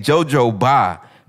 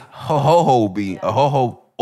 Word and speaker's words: jojoba: I was saying jojoba, but apparently it's ho-ho-ho oil or whatever like that.